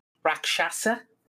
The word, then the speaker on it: Rakshasa: There's also mention in the song of Rakshasa. Am I saying that right? Rakshasa? – Yeah, the tiger people.